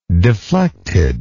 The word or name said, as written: deflected